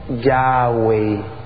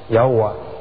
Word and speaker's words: Yahweh: Y salía sonando algo como Yahweh, o ya, en griego, en español, Yahweh, si lo pronunciamos en español. En inglés suena como Yahová.